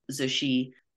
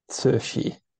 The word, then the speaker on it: Cixi: In English, it's spelled Cixi, which is not something that English speakers come across too often. I understand it's pronounced Cixi.